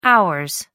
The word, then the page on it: hours: American English